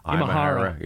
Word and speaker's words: Imahara: Imahara.